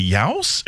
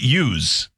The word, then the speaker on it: youse: Yowse? Is it use? Must be use, but it's spelled Y-O-U-S-E.